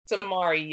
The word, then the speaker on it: Samarie: Samarie, yes.